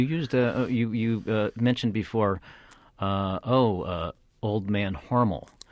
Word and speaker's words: Hormel: You wanted to, you know, you were talking about Hormel rhymes with normal was how people were supposed to pronounce it.